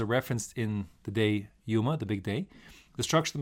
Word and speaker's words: Yoma: The word Yoma, or probably more correctly pronounced Yuma,